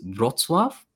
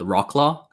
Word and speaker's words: Wrocław: The actual Polish pronunciation of the city that I live in is Wrocław, but you might see it spelled Rocklaw, so you know, like, the W in Polish is a B, so it's Wrocław, right?